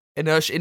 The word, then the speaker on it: inertia: But inertia inertia is not a tide. – Just oh, inertia. let, oh, the T's yeah. pronounced sh- right.